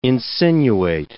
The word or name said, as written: insinuate